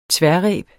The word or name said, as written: tværreb